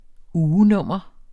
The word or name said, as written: ugenummer